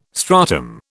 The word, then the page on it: stratum: British English